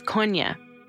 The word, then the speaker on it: Kony: The proper pronunciation is Konya.